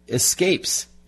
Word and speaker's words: Esquapes: It's called Escapes, and it's spelled E-S-Q-U-A-P-E-S.